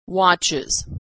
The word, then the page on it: watches: Iz